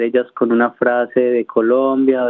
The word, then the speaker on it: Colombia: Bueno, todo comenzó en el 2020, en febrero, para ser más exactos, cuando yo quería una camiseta que dijera It's Colombia, not Columbia, pero porque es una palabra que muchas personas acá en Australia pronuncian mal. El nombre de nuestro país lo pronuncian Colombia,